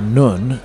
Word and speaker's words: Nun: It's the letter N in Arabic, or Nun, as they pronounce it,